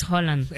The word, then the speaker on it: Haaland: Dicen que su pronunciación es Haaland.